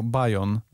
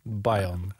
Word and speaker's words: Bayon: Pisze się B-A-Y-O-N i my to wymawiamy jako Bajon. Bajon.